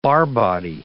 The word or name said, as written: Barr body